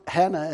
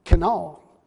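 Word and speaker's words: Kenal (חנה): And actually, Hannah is not pronounced Hannah, it's actually Kenal. Is actually how it's to be pronounced.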